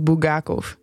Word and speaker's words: Bulgakov: Namelijk De Meester en Margarita van Michail Bulgakov.